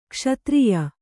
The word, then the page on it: ಕ್ಷತ್ರಿಯಿ: kṣatriyi